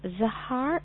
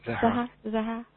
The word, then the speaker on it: Zehra: Z-E-H-R-A? – Yes. – Sahar. And where from? – Canada. Milton, Canada. – Milton. Hello. Zahar. Am I saying it right? Sahar? – Zahra, I think. – Zahra.